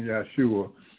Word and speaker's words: Yeshua: And I keep saying Yeshua because I want you all to pronounce the name right.